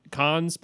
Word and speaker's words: Khan: I think it's the question is, are they bringing him as a backup or like is it taking Khan's place? Is that how you pronounce his name? Khan? – Right. Yeah.